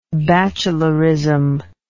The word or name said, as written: bachelorism